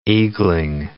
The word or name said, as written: eagling